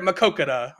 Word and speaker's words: Maquoketa: He's like, I'm here at Maquoketa.